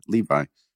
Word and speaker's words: Levi: Just talking about that yesterday with Zach Levi, whose name I kept mispronouncing, Levy.